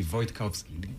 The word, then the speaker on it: Wojtkowski: So it's Renek Wojtkowski. In Polish, I'm originally from Poland. It'd be Wojtkowski.